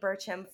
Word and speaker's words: Burcham: Bailey Burcham from Los Angeles.